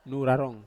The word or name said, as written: nu rarong